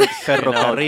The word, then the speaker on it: ferrocarril: I don't do the with the R. – Ferrocarril. – You know. Yeah, there you ferrocarril.